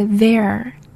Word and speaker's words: there: 记住那个 there. – There. – There. TH 那个 sound. Yes, 一定要咬住舌尖。